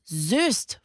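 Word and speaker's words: Soest: Und ich stelle euch Simone aus Söst vor.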